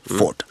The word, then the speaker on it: Beaufort: Han heter the 11th Duke of Beaufort. – Okay. – Och det stavas B-A-U-F-O-R-T och det är B-O-W uttalas. – Ja, ja, Beaufort.